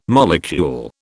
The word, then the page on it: molecule: British English